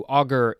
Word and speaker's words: augur: I mean, it could be good, but anyway, we are going to move on to augur, a u g u r. Um, is this? Yep, it looks like it's pronounced the same way as auger from two episodes ago.